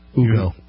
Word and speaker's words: Hugo: Is it Hugo? – Hugo. – Hugo Chavez. – You wouldn't pronounce the H, right? – Yeah, I guess you wouldn't.